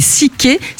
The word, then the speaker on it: Sekai: Je vous rappelle son nom, elle s'appelle Sickey. Ça s'écrit S-E-K-A-I. – Alors, S-E-K-A-I.